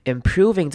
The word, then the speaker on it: improving: Improving 这个字尾要注意的是开头的 i 要念成短母音 i 的声音，improving。 Improving 不是 im，所以不要念成长母音 e 的声音了，这个地方很容易念错。 Improving 才是对的。